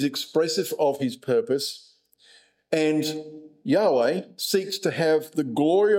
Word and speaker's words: Yahweh: As we'll see here, that's the Hebrew word, and it's pronounced Yahweh, and it's the divine name, and its meaning is, I will be who I will be.